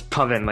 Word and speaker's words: Puvin: It's Puvin, like oven.